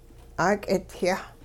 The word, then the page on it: 'aak'idyah: hupa